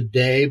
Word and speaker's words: Te: uh, and Benjamin Hoff wrote this amazing book, and the uh, the sequel to it is The Day, but that's spelled T E which is a, a Taoist concept.